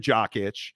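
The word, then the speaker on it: Jokić: And uh, Nikola Jakic. – Um, Menace Parade. I love mispronouncing names. Uh, n- here, I'll say it correctly. Nikola Jokic.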